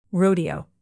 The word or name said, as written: rodeo